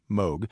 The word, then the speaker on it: Moog: It's spelled Moog. It's pronounced Moog.